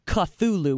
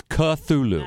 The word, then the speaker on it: Cthulhu: involved the word Cthulhu, which I mispronounced with a ch sound.